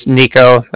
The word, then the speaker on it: Niko: My name's Nico.